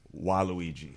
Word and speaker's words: Waluigi: And definitely, you're going to get all the love and the props if you dress like Waluigi.